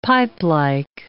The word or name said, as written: pipelike